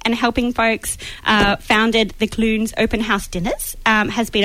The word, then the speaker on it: Clunes: She lives in Clunes.